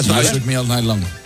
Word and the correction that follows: Shook Me All Night Long: Me cuesta el Shook Me All Night Long.